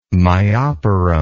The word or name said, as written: myoporum